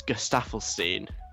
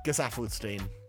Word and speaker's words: Gesaffelstein: And the last question about the new website is from Yasmin, saying, how do you pronounce Gustafelstein? – I see how this has a connection to the new website. It's pronounced Gesaffelstein.